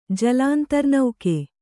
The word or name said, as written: ಜಲಾಂತರ್ನೌಕೆ